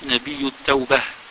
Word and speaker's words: نبي التوبة: ونبي التوبة، نوتي نبي التوبة، ميان لي dans la lecture on prononce Nabiyu Tawbah.